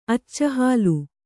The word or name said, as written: ಅಚ್ಚಹಾಲು